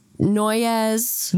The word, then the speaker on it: Noyes: And you're probably imagining that his last name is spelt like in a way that would seem like it's pronounced Noyes, but it's not. It's spelt N-O-Y-E-S. It's spelt no yes. Because this man can't right. make up his mind. So you'd think that it's pronounced Noyes. – Noyes. – Or Noyes. – I mean, Noyes. – But no, it's Noyes. I hate it.